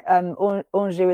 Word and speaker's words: Anjou: So yeah, no, like Anjou is my middle name. And it rhymes with reviews.